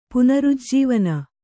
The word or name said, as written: ಪುನರುಜ್ಜೀವನ